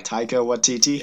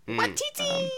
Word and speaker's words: Taika Waititi: Taika Waititi. – Yeah, that guy. – Waititi.